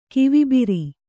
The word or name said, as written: ಕಿವಿಬಿರಿ